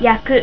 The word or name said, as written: yaku